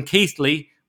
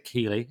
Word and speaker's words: Keighley: I was hosting in Keighley, which Josh couldn't pronounce because he's not northern. He said Keeley.